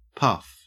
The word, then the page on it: puff: Australian English